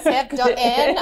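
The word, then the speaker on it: Sev.en: Is it Sev-N? – Sev-N. I know. I thought there was a typo earlier today, and then I wrote Checked it myself, it. And I was like, oh, no, that's how you say it.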